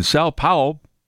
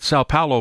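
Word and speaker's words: São Paulo: And then tonight you've got the Eagles are going to be playing the Packers in Sao Paulo. Is I'm saying that right? Sao Paulo, Brazil.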